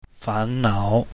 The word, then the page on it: fan2nao3: Mandarin